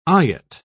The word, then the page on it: eyot: British English